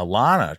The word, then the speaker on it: Lana: Lana rhymes with banana. Emily turns him away, saying that Lana already took off.